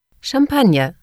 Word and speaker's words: Champagne: Champagne.